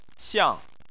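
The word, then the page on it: xiang: xiang4; tone 4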